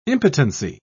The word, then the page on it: impotency: British English